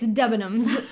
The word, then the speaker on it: Debenhams: And then finally one day my friend's like, it's Debenhams. Say it with me. Debenhams.